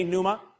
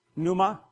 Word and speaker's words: pneuma: Everybody say pneuma. The P is, now you know Greek. Okay. The P is silent, pneuma.